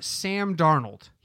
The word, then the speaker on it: Darnold: Now, the metric in terms of pure points versus salary loves Sam Darnold.